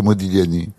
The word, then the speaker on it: Modigliani: Oui, d'accord, mais enfin, faut pas prononcer le G dans Modigliani.